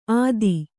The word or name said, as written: ಅತ್ತಿ